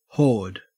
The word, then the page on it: horde: Australian English